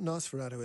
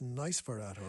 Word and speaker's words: Nysferatu: Now, it's not Nosferatu, it's Nysferatu, or NY.